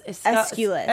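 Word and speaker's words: Escalus: I can never say his name. Aeschylus? – Aeschylus. – Aeschylus. Yes? – Yes.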